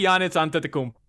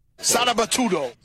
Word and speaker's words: Giannis Antetokounmpo: But again, Fat Joe pronouncing finals MVP, two-time NBA MVP Giannis Antetokounmpo. – Sarabatudo.